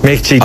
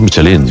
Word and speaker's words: Michelin: Michelin. A ver, aquí en la Península Ibérica hacemos Michelin.